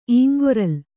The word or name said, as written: ಇಂಗೊರಲ್